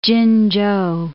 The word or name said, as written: Chin-chou